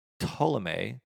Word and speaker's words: Ptolemy: She was the daughter of Ptolemy, which is how you pronounce it, but there's a P in front of it.